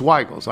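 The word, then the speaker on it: Zweigle's: Zweigels, the creator of the White Hot, Hoffman who, should be said with a long I sound. Okay, rhyming with higher high. So Zweigels. It's Zweigels.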